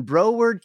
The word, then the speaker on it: Broward: It's Tuesday, and Sam and Dean are in Broward County, Florida.